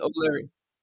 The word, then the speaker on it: O'Leary: My mother tongue is Gaelic, so that's the Gaelic fashion. So in English, it would be O'Leary, spelled differently. So I use the Gaelic spelling and pronunciation.